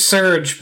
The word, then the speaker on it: Serge: Then it's Surge, but it's pronounced like the energy drink from the 90s.